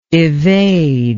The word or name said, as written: evadingly